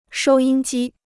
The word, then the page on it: 收音机: shōu yīn jī